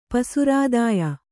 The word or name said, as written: ಪಸುರಾದಾಯ